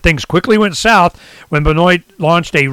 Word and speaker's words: Benoit: B-E-N-O-I-T, how you say it, Benoit,